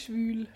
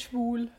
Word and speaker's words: schwül: Schwül. Schwül. Nicht schwul.